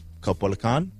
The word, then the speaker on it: Caupolicán: And then we've got back in Chile, Teatro Caupolicán, I think. – Mm-hmm. – Is how you say it.